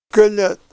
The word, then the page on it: gal'et: Ninilchik Russian